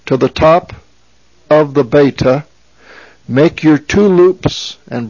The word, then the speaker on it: beta: It is pronounced beta. It is the B sound, as in the word boy. It's B.